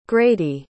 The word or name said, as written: grady